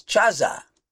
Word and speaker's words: chazah: And they often say that that word is Cheze, C-H-A-Z-E-H in our alphabet. But there's another word they say is Chaza, C-H-A-Z-A-H, spelled exactly the same way in the Hebrew.